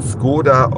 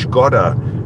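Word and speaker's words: Škoda: Fahre also jetzt einen Skoda Octavia, obwohl er ja eigentlich Skoda Octavia heißt, aber es wird nicht mehr so ausgesprochen, weil Volkswagen das dem deutschen Mund nicht zumuten möchte, dass dieses slawische Skoda, so wie die Firma wirklich heißt, im Alltag verwendet wird.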